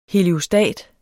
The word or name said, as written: heliostat